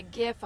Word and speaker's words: GIF: And a GIF, a hard G.